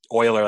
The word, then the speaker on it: Euler: Like Euler Beats, but it's Euler, like the mathematician, his last name's Euler.